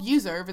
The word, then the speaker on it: Uzurv: It's called Userv. It's spelled UZURV.